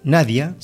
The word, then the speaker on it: Nadje: En este caso, la voz de Nadia Nordhuis, Nadia, sería la pronunciación, Nadia Nordhuis, así como más o menos se dice, esta chica que, pero se me cruzó la data, acá, Nadia Nordhuis.